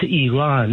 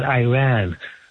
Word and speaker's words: Iran: I was there, that guest of yours from Idaho, it's pronounced Iran, not Iran. Like, we don't say Italy, we say Italia.